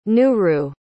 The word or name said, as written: nuru